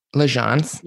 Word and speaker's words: L'Agence: And let me tell you, I love L'Agence. Am I saying it right? – L'Agence. – L'Agence. Sorry.